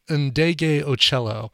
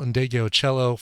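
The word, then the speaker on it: Ndegeocello: I'm going to pronounce it correctly this time. It's Michelle Ndege Ocello. Michelle Ndege Ocello from her 2018 album Ventriloquism, a gorgeous version of Sometimes It Snows in April.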